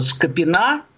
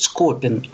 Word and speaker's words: Скопин: И вот там когда на улице города Скопина, хотя его почему-то все произносят Скопин, а я знаю, что он Скопин, но неважно.